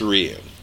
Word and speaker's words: Ryu: Capcom. Capcom said it's Ryu. – Ryu. – Now the 1994 and the movie called him Ryu, and a lot of fans have called him Ryu through the years.